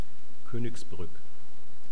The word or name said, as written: koenigsbrueck